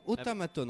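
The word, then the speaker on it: Otamatone: Lotamaton. Comme ça se prononce, hein. – Ouais. – Oh, ah non, Otamaton, en fait. Il n'y a pas de L.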